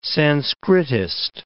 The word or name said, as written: Sanskritist